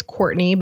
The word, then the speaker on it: Cortney: It's, but the important thing to keep in mind is that my name is Cortney, but it's spelled without a U. – Yes. – So if you try to find me and you type in C-O-U-R, you won't find me.